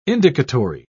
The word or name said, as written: indicatory